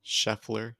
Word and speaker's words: Scheffler: Scheffler, yeah.